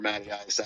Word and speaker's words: Matttyice: I wanted to do two T's, but there was an imposter Matttyice out there, so I had to roll with the three T's.